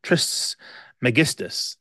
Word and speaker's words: Trismegistus: Trismegistus.